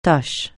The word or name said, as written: tâche